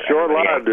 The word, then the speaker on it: short-lived: Yeah, short-lived is the preferred traditional pronunciation. It's a thing with a short life.